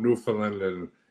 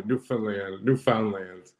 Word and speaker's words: Newfoundland: And so he's always talking about Newfoundland, and I'm even pronouncing it wrong now. Newfoundland. They kept on telling me Newfoundland.